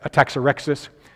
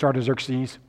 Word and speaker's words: Artaxerxes: The preacher where I grew up used to pronounce that Ataxerxes, but I'm pretty sure it's Artaxerxes, so that's what we'll go with.